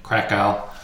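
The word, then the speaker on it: Kraków: Oh, I'm going to I'll say the American pronunciation, Krakow.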